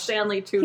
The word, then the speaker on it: Stanley Tucci: Stanley Tucci.